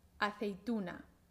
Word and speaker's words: aceituna: Vamos primero a ver cuántas sílabas tiene aceituna, aceituna. Tiene cuatro sílabas. A, cei, tu, na, aceituna. La sílaba fuerte es tu, aceituna, ta tata, aceituna, tata tata, aceituna.